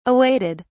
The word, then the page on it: awaited: British English